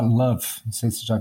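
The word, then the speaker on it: LÖVE: É uma coisa que é alemã, tem origem alemã, né, se pronuncia Love, aquele O meio alemão que é um ambiente de, de, de jogos, tem é, gráfico, umas bibliotecas, tudo, tudo programado em Lua.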